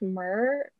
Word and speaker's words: Mer: So my TikTok is Kainath Murr because it's spelled K-A-I-N-A-T-H and then M-E-R.